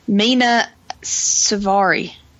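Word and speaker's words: Mena Suvari: And Xander Berkeley, Ethan Embry, Colin Hanks playing a very obnoxious character, Mina Suvari.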